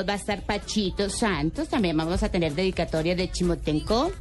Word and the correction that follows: Timochenko: No, sí, así es, ¿no? Se pronuncia así. Hmm. No, no, Timochenko.